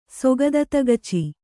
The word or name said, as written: ಸೊಗದತಗಚಿ